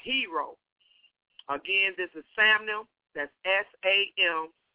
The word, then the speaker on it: Samnem: But how I've been saying it is I say Samnem. That's how I pronounce it. S-A-M-N-E-M. Again, S-A-M-N-E-M. Samnem. That's how I say it.